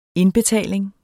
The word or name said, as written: indbetaling